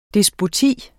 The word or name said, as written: despoti